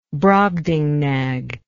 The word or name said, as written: Brobdingnag